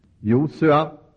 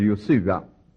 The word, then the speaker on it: Josua: När språkbruket är vacklande i fråga om uttalet av ett namn, må frihet råda vid dess betoning. Josua eller Josua. Varsågod och välj.